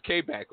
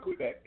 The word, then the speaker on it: Quebec: And that was one of the it's Quebec, right? Did I say it right? – Yeah. Oh, some say Quebec, but Quebec, yeah.